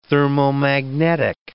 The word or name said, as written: thermomagnetic